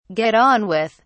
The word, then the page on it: get on with: British English